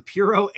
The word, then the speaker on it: Puro: and then the next, the Puro, I always try and say the R with a D and I can't. The Puro Amber.